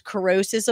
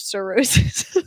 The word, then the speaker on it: cirrhosis: My favorite thing was corrosis of the liver instead of cirrhosis.